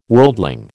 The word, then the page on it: worldling: American English